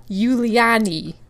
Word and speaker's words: Julianne: Now, another thing I'd like to preface is that Julianne Kupke is German, and so her name is Juliani, but it's spelled Julian, like a Julianne Moore, that actress. – Oh, Julianne Moore, yeah. – Mm-hmm. Yeah, so I'm going to be saying Julianne, but her actual name is Juliani.